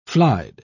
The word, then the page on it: flied: British English